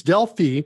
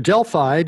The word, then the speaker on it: Delphi: Yeah, I actually started it for Borland's Delphi, or Delphi, depending on where you are in the world, how you say it, which is a Pascal-based IDE compiler.